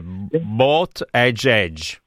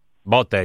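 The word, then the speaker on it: Buttigieg: Valeria ci dice che è bot edge. – Eh? – Bot edge. – Bot no. – Ah, la pronuncia. – Il suo comitato elettorale dice di pronunciarlo così.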